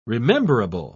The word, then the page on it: rememberable: British English